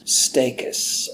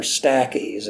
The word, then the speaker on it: Stachys: It's a member of the mint family, and the name of it is Stachys, or Stachys. It's spelled S-T-A-C-H-Y-S.